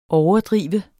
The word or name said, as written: overdrev